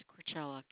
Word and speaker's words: Krachala: Last name is Crachella. K-R-A-C-H-A-L-A.